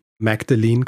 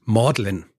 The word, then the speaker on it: Magdalene: Dann das College, das er in Cambridge besucht hat, heißt nicht Magdalene College, so wie ich es ausgesprochen habe, sondern Magdalene. Mhm. Magdalene wird es ausgesprochen.